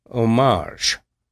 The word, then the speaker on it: homage: And what's this homage stuff? – That's the way you pronounce it, homage.